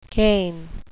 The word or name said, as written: cain